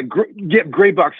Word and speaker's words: Graibox: Yeah, Graibox. So it's spelled with an AI.